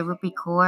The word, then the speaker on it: Rupi Kaur: It's called Milk and Honey by Rupi Kaur, I think her name. That's how you pronounce it.